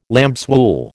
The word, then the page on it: lambswool: American English